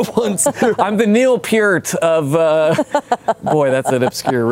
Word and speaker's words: Peart: Reeling from your Neil Peart reference.